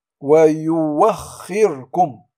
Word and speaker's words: ويؤخركم: هنا كذلك ويوخركم ولا نقول ويؤخركم.